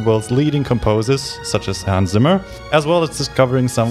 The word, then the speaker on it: Hans Zimmer: It's Hans Zimmer.